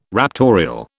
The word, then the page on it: raptorial: British English